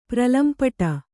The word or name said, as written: ಪ್ರಲಂಪಟ